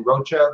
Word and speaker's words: Rocha: My name is spelled Joey like a baby kangaroo, Joey, and then Rocha like a cockroach, R-O-C-H-A.